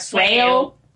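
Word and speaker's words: Swale: Se escribe Suale, pero se debe pronunciar Sueo.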